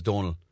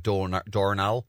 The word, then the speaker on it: Donal: And he went straight up to the, nobody could pronounce his name because his name is Donal. So everyone used to call him like Dornal and everything.